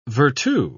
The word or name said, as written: virtu